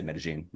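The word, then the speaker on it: Medellín: And so going to Medellin, Colombia, I should say Medellin, that's actually how it's said.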